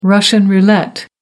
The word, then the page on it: russian roulette: American English